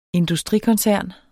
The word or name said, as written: industrikoncern